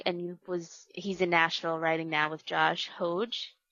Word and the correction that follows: Hoge: Hogue. – Hogue. – Sorry. We've been saying his name wrong.